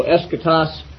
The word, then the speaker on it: eschatos: Nobody knows exactly how the Omicron in the Greek was pronounced in ancient times, but I tend to give it a short O pronunciation. So eschatos and logos.